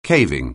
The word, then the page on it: caving: anglais